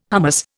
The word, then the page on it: hummus: Indian